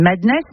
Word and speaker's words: MDNS: Ultra Violent, avec, je crois qu'on dit Madness, mais ça s'écrit MDNS.